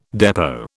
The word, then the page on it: depot: British English